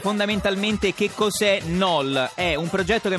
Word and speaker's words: KNOL: ovvero l'annuncio da parte di Google di KNOL, si scrive K-N-O-L,